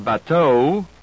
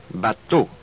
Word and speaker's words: bateau: Your lips and tongue do not move while you pronounce this vowel. Instead of bateau, be sure you say bateau.